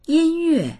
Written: yin1yue4

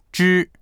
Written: zhi1

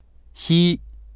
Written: xi1

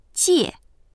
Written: jie4

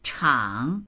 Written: chang3

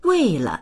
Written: wei4le

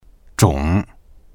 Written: zhong3

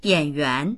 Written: yan3yuan2

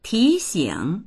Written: ti2xing3

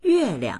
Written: yue4liang

